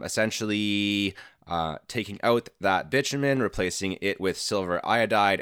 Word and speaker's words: bitumen: I think I called it butamin powder in the last episode. It's actually pronounced bitumen powder.